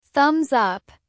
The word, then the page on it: thumbs up: American English